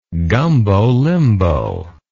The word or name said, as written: gumbo limbo